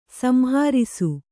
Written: ಸಂಹರ್ಷ

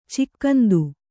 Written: ಚಿಕ್ಕಂದು

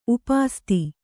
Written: ಉಪಸ್ಥ

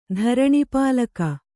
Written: ಧರಣಿಪಾಲಕ

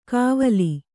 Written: ಕವಾಲಿ

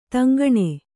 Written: ತೆಂಕಣ

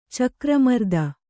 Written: ಚಕ್ರಮರ್ದನ

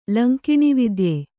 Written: ಲಂಕಿಣಿವಿದ್ಯೆ

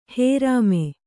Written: ಹರಾಮ

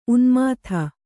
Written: ಉನ್ಮೋದ

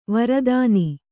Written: ವೀರತನ

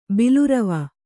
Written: ಬಿಲುರವ

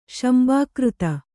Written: ಶಂಬಾಕೃತ

